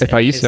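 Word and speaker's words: El País: El Pais. – El Pais, ja.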